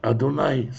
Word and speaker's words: Адонай: «Адунай» с камацем – это Бог, Адонай с Патахом, хотя произносится в ашкеназском произношении одинаково, это Господин.